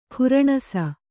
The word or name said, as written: ಖುರಣಸ